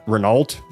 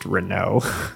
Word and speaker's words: Renault: Anyway, at this point, the organization that Mograine and his two sons, everyone likes to call him Renault, but it's actually pronounced Renault, if it's a French, because it's a French name.